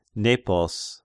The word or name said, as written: Nepos